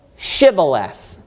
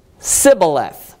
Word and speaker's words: Shibboleth: If he said, "Nay," then said they unto him, "Say now, Shibboleth." And he said, watch this, "Sibboleth," for he could not frame to pronounce it right.